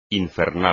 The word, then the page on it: infernal: espagnol